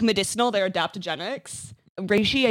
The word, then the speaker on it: reishi: OP is an asshole, but miss me with that reishi business. I'm not sure how to say this word. It's R-E-I-S-H-I.